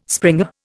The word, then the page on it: springer: Indian